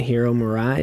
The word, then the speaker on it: Hiro Murai: Hiro Murai, is that how we said we're going to pronounce his name? – Yeah.